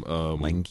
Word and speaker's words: Lange: And Jessica Lange was with him, Lange, however you say her name. – Lange, yeah.